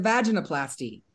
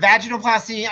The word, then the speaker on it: vaginoplasty: I've heard vaginoplasty. I've heard vaginoplasty. I've heard it pronounced both. You can make the A long or soft. – How would you say it? – I say it vaginoplasty, but a vaginoplasty will also be the common terminology.